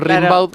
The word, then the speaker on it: Rimbaud: Claro, como se pronuncia Rimbaud, pero es Rimbaud.